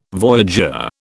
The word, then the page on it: voyageur: British English